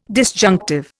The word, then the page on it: disjunctive: Indian